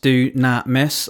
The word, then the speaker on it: dunamis: And so that word dunamis, which is the word that Paul uses there in that verse 5, the Greek word dunamis, dunamis, that's how you'd pronounce it.